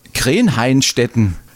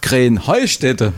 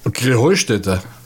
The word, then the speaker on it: Krähenheustätte: Ja, ihr sagt immer Krähenhainstetten. Und dabei heißt es doch Krähenheustätte, oder wie? – Krähenheustätte. – Ja, genau, und das ist die Betonung auf dem Heu.